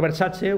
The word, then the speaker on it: Versace: La implantación ya no rítmica sino métrica de este estilo se encuentra principalmente en la canción de Migos, Versace. Bien, siendo Versace una palabra con tres sílabas, que viene muy al pelo, ¿no?